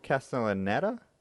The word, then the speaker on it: Castellaneta: You could probably pronounce that more correctly. Castellaneta? – I'm fairly sure it's, yeah.